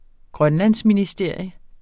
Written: grønlandsministerium

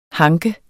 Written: hank

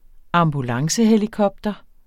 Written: ambulancehelikopter

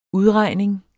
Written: udregning

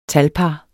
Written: talpar

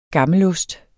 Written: gammelost

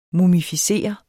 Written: mumificere

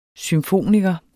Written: symfoniker

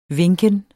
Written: vinken

